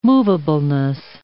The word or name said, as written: movableness